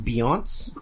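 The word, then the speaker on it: Beyoncé: I believe it's pronounced Beyonce.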